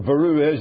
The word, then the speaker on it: bureau: You people would have no idea what the Beru is. That's a Belfast pronunciation of the French word bureau.